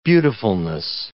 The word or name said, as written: beautifulness